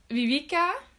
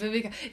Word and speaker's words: Vivica: Und in dieser Sequel möchte die Vivica, heißt die so, Vivica? Oder Vivica. Ja, Vivica. Man merkt schon gleich, ich habe es italienisch ausgesprochen, weil es gleich um den italienischen Godfather geht.